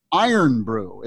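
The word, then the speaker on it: Irn-Bru: And then one of their big local drinks is called Iron Brew.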